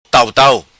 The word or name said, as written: Tau tau